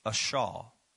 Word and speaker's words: asah: The word do there, and let me give it to you, is it's ashah. A-S-A-H. Ashah. Ashah, that's kind of the pronunciation.